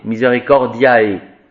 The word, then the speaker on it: Misericordiae: A-E, c'est E, hein, c'est pas A-E, Misericordiae.